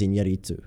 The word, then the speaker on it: Iñárritu: Alejandro González Iñárritu.